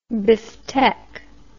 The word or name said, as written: bifteck